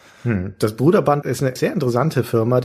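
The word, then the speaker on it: Broderbund: Es heißt Gut. Bruderband. – Bruderband. – Mit langem Total U. Total anders, als man es intuitiv sprechen würde. – Genau, intuitiv würde man ja Brüderbund sagen oder so.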